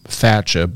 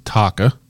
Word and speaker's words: Thacha: So still one winner, but so on the deer side, this is their new, it's funny because I've been pronouncing it Thatcha, but I apparently it's Taka. – Okay. – T-H-A-C-H-A.